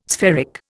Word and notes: spheric: Indian

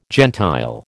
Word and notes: gentile: American English

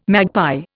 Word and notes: magpie: Indian

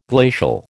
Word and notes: glacial: American English

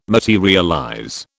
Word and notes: materialize: British English

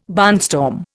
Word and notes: barnstorm: Indian

